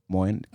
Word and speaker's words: Moen: They call it Moen. That's the main island. – How do you spell it? – Moen. Call it M-O-E-N. Moen. – M-O-E-N. M-O-E-N.